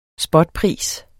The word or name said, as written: spotpris